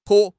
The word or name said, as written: 泼